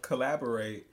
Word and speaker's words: Caleborate: Collaborate. I've talked about him in the past. – He's sound friendly. – His name is spelled like Caleb, but C-A-L-E-B-O-R-A-T-E.